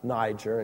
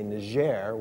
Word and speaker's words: Niger: In fact, one of the countries of North Central Africa, we in English pronounce it Niger, in French, or there, they would say Niger, which again means black.